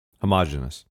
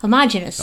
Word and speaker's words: homogenous: Homogenous. – Yes, that's how I say it. You are correct. Yep. Homogenous.